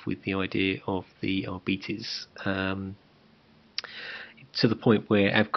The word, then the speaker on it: Arbites: So that's the Arbites.